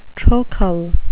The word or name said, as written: trochal